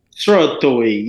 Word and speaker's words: Srotoy: The dance track, I think this one is headed for the charts, and I can't pronounce it properly. So, Srotoy? – Srotoy, yes, Srotoy, yes.